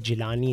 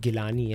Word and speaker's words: Gilani: I was just talking to Sarwat about her name pronunciation, about, you know, she was just mentioning that some people say Sarwat Jilani instead of Gilani.